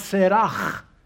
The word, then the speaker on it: Sarah: Sarai becomes Sarah. I'm pronouncing the H hard, as they would in the Jewish, so that you can see it.